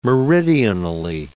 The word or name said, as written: meridionally